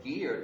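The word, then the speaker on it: Guier: and one was a man called William Guy, or Gear, depending how you like to pronounce it.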